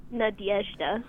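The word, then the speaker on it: Nadezhda: That's not exactly how she pronounced it. – Nadiezhda. – Yeah, it's more like Nadiezhda. – Yeah, there's like a Y sound in there.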